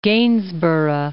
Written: Gainsborough

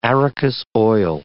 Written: arachis oil

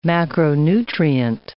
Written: macronutrient

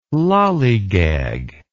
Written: lallygag

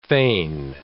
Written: fain

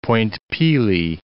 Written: Point Pelee National Park

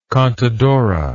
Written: contadora

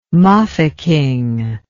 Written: mafeking

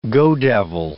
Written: go-devil

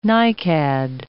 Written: nicad

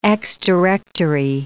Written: ex-directory

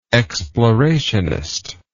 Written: explorationist

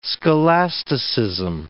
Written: scholasticism

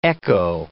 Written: echo

